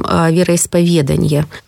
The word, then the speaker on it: вероисповедание: Больше всего и чаще всего, кстати, допускают ошибки и в устной речи в первую очередь со словом «вероисповедание». А ведь достаточно просто запомнить, что это от сочетания «веру исповедать». И понятное дело, что это сохраняется, это ударение.